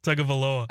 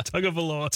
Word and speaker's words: Tagovailoa: Tagovailoa. – I don't think Chad said it right. On the, Tagovailoa.